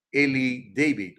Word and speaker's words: Eli David: Y básicamente lo que dice el doctor Eli David o Eli David, se escribe Eli así como Elías, Eli y David.